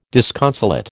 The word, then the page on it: disconsolate: American English